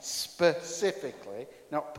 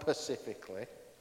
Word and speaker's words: specifically: Specifically, and I did say that right, specifically, not pacifically.